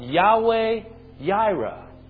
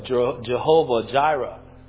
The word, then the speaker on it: Yahweh Yireh: At the end of that entire trial, he names that place, right, Yahweh Yireh. Or you might have heard it in more of the colloquial way of Jehovah Jireh. Right? That's just another pronunciation.